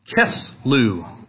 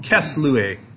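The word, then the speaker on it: Kislev: The ninth month in the Bible is called Kislew, or Kislewe, depending on how you want to pronounce that.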